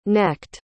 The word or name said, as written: necked